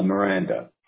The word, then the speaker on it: Miranda: Miranda. – Okay, I have to type it out to see it and spell it. M-I-R-A-N-D-A.